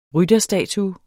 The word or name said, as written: rytterstatue